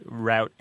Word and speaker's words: route: Route and root are both very common pronunciations of the word. They battle it out. You'll find that root, as a pronunciation of R-O-U-T-E, is more common generally in the Northeast and the Atlantic seaboard, while route is more common in places like Illinois, Pennsylvania, Ohio.